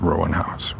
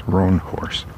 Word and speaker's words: Roanhorse: Rebecca Roanhorse. Not Roanhouse. Roanhorse. I know her name. I know how to pronounce it.